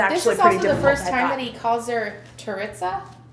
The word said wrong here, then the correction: Tirzah: Tirzah. Tirzah. – Wow. – The pronunciations. Tirzah.